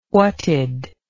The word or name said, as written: whatd